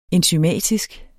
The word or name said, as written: enzymatisk